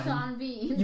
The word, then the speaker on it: Sean Bean: Yeah, because in England, S E A N is pronounced Sean. – Sean. Yeah. – I think he's British. – Yes. That's the worst way to spell it, by the way. – Yeah. So it's pronounced Sean. – Yeah. – But then his last name is Bean. B E A N. But you can't. – But you, those words should rhyme.